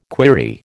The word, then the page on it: query: American English